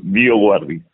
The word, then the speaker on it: Dioguardi: ¿Lo dije bien? ¿Pasta tu apellido? ¿Dioguardi está bien? O, Perfecto, perfecto. Así, Dioguardi. Exacto.